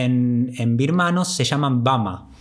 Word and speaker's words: Burma: Pero si lo decimos en inglés, Burma se pronuncia Bama y en, en, birmano el nombre del país es Bama.